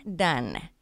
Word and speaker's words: Dunn: Geek Love by Katherine Dunn. Okay. And it's D-U-N-N. – Right. – D-U-N-N, pronounced like your relationship with Devin when you hate it.